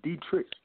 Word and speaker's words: Detrich: My nickname, my name is Detrich. Actually, my name is Dietrich. My name is German. But a lot of people can't say my name. The proper way to say it in English is Detrich.